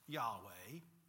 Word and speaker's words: Yahweh: Of course, in Hebrew, that's often left untranslated, and we believe that it was pronounced Yahweh.